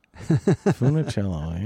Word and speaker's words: Funicello: And what a cool last name, Funicello. – Yeah. – Four syllables. – Funicello, yeah.